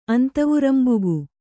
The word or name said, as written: ಅಂತವುರಂಬುಗು